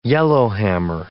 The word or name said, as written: yellowhammer